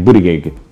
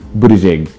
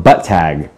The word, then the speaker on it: Buttigieg: Somebody called him butt tag. I'm like, are you kidding me? What is wrong with you? Like, get it together. Learn how to pronounce these names.